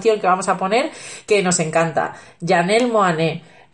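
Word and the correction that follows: Monáe: Eh, Monae. – Monae Ah,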